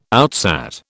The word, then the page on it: outsat: British English